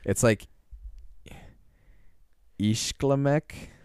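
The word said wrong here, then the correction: Iglishmêk: That's really close. – What is it? – Eaglishmek. – Oh, Eaglishmek. Okay, I just missed. – You just switched around.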